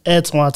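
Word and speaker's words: Antwan: And follow me, Antoine Thomas, your host.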